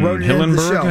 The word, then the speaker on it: Hillenburg: Steven Hillenburg, I think is how you say his name. – Yes. – Right.